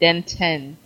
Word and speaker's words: Denton: Although everybody says the town Denton, and whenever he introduced himself to me, he said Denton, so he made sure to t- pronounce the T.